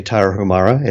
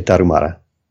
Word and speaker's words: Tarahumara: Okay, so in fact, it wasn't that they said, I heard about the Taro, what is it, Taro Humara? – Well, if you want to say it like an American, you say Tarahumara. If you want to say it like someone who speaks Spanish, you say Tarumara.